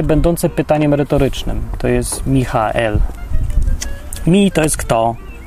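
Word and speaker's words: Michael (מיכאל): W oryginale jest Michael, tak, przez to twarde H, Michael. Naprawdę się to tak wymawia, ja się nie wygupiam z tym H. To się tak mówi, harcząco.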